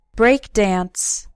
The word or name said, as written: breakdance